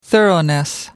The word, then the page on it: thoroughness: British English